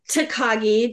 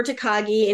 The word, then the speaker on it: Takagi: No, it's Takagi, Jennifer Takagi. Anybody who watched like Karate Kid, Mr. Miyagi, Takagi Miyagi.